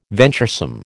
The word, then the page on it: venturesome: American English